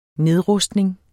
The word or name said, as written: nedrustning